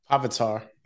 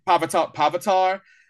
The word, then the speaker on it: Pavitar: Pitar. – Pavitar. – Pavitar.